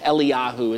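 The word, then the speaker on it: Eliyahu (אליהו): Eliyahu is how you say that in Hebrew.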